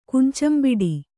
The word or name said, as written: ಕುಂಚಂಬಿಡಿ